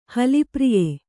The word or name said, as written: ಹಲಿಪ್ರಿಯ